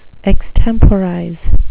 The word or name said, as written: extemporize